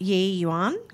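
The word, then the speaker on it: Yi Yuan: So visitors are also invited to enter Diagum Sand Precinct as part of the After Dark Trail, where the is it Ye Yuan Gardens? – Yep. – Forgive me if I've mispronounced that.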